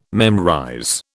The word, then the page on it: memorize: British English